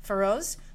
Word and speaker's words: Faroese: F-A-R-O-E-S-E, Feroze, musical group bearing the name Valraven, and they play a form of traditional music.